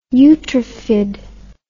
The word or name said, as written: eutrophied